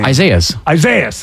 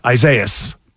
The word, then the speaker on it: Isaias: I don't know how to say the name. – Isaias. – Okay, it's like Dr. Zaius. Isaias.